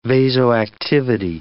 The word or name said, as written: vasoactivity